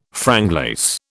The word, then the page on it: franglais: British English